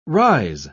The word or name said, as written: rise